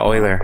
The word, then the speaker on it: Euler: So it's pronounced Euler? – Yeah, Euler. Okay.